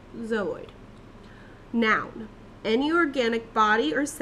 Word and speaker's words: zooid: So it is pronounced zooid. Zooid. – Zooid. Okay. – Zooid. It's spelled Z-O-O-I-D.